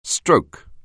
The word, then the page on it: stroke: anglais